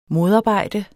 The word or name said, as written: modarbejde